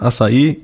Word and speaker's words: Açaí: Die korrekte Aussprache hatte, da das Wort Açaí korrekt lauten muss.